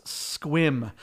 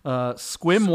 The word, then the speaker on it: Sequim: S-E-Q-U-I-M? You are not. – How do you say it? – A lot of people mistake it for Sequim, but it is pronounced Squim. Squim, Washington is out Squim on the Washington.